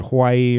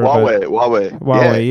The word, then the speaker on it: Huawei: I'm not sure how you pronounce it, Hawaii or whatever. – Huawei.